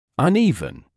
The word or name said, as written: uneven